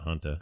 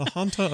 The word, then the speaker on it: Hanta: It's H-A-N-T-A, so it must be Hanta, not Hunta.